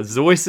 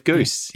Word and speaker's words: Seuss: Now, Zeus is interesting. Because that is how... – That was how it was originally pronounced. – That's his German name. – It rhymes with a voice. – It's a German name. – Yeah, and but he's... – He went with Goose.